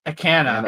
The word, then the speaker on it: Akana: How do you say her name? Her last name? – Anna Akana. Akana.